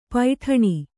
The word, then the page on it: ಪೈಟಾಣಿ: paiṭāṇi